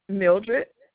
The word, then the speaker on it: Mildred: Mildred, Could you spell that, please?